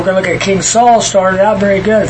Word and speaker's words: Saul: Now his name is Saul. So think of it as two syllables.